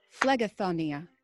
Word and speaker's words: Phlegethontia: Phlegethontia. Phlegethontia. You pronounced it J. It's G.